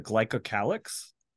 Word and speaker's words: glycocalyx: Tell me if I'm pronouncing this correctly. The glycocalyx? – Yeah.